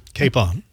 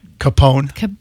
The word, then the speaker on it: capon: If I went to Publix this afternoon and said, "Do you have capon?" – Capone. Yes, not really. – You just got the emphasis on the wrong syllable.